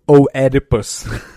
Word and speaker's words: Oedipus: Or as I, have as I read it in fifth grade, Oedipus. – Oedipus. – Yeah, you read the whole O. The O is silent.